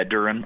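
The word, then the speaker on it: Duren: Duran, how do you say his last name? Duran? – Yeah, Duran. Uh-huh.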